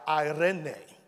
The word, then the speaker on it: eirene: Okay, when you look up that word peace and you go into the concordance, it's a word that's spelled E-I-R-E-N-E, if you're taking notes, E-I-R-E-N-E. And it's the word for peace. And it's pronounced Irene. Is how it's pronounced. It looks like Irene, but that's not how it's said.